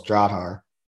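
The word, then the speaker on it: Drahthaar: And by the way, that Drahar, I don't remember his name. Sawyer's beautiful, but the Drahar is absolutely gorgeous. And if I'm mispronouncing that, let me know. – Yeah, no, yeah, you're pretty close. Drahar.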